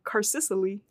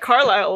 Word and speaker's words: Carlisle: A friend of mine at work, she told me she pronounced it when she read the book Carsicily. Because it's spelled really weird.